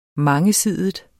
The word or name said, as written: mangesidig